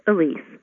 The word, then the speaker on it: Elise: My first name is French. It's pronounced Elise. Like when you rent an apartment, you sign Elise.